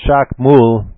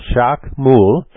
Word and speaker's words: Chacmool: As I said, Jacques Moule, the way it's spelled is C-H-A-C-M-O-O-L, Jacques Moule.